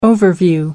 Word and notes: overview: American English

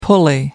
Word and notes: pulley: American English

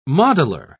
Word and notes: modeler: British English

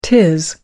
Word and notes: tis: American English

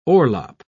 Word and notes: orlop: British English

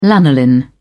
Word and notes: lanolin: British English

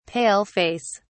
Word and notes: pale face: British English

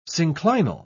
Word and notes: synclinal: British English

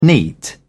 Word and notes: neat: British English

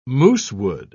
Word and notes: moosewood: British English

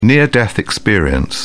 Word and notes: near-death experience: British English

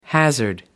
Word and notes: hazard: American English

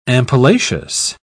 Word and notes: ampullaceous: British English

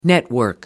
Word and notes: network: American English

